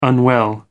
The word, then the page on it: unwell: American English